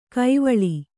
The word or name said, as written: ಕಾವಳ